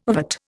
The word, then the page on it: ovate: Indian